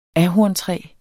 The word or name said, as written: ahorntræ